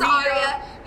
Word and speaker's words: Aria: Aria. It's Aria. You know, I know how to say it because they've come up to our booth about 8 times saying, you're not supposed to be here.